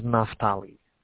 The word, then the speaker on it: Naftali: My name is Naftali.